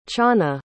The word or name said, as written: channah